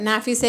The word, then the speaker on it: Nafiseh: Nafise is NAFISEH, and Soleimani is SOLEYMANI.